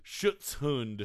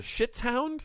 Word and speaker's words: Schutzhund: Schutzhund. – Schutzhound? – Schutzhund. – Protection Schutzhound? – Dog. In English, it's protection dog. – Yeah, we'll just call it protection dog. You can be a Schutzhund level 1, a Schutzhund level 2, or a Schutzhund level 3.